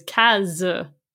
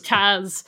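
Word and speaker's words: Cas: But the problem with that is that whenever anyone hears my name before they see it spelled, they assume there are two S's in it. And anytime someone sees the spelling of my name before they hear it pronounced, they think my name is Kaz, like with a Z. – Mm. – But Kaz. – No, it's Kaz.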